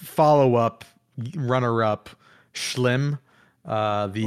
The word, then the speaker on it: Schim: There isn't, but that's how you pronounce it. It's Dutch. S C H I M is how you spell it, and it's pronounced Schlim.